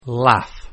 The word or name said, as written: laugh